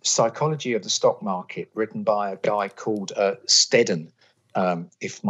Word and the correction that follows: Selden: my memory, I always get his surname wrong. Yes, Selden, sorry, S-E-L-D-E-N, Psychology of the Stock Market, 1912.